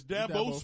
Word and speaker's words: Dabo: Dabo, I think, is the right way to pronounce it. – Or is it Dabo? What is Dabo. – It? – It's Dabo Sweeney.